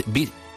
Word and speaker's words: Bis: Con libreto en francés de Étienne de Jouy, y además de Hippolyte Bis, o Hippolyte Bis, la S en francés no se pronuncia al final.